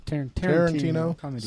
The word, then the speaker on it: Tarantino: Tarantino.